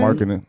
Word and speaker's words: Markkanen: Markkanen.